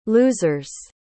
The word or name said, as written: losers